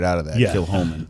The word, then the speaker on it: Kilchoman: The whiskey's pronounced Kilhoman. – Kilhoman, okay. – Exactly how it's not spelled. – This is spelled, by the way, Kilchoman.